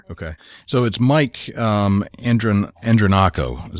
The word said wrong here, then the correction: Andronico: Is that how you pronounce that? – not quite Andronico.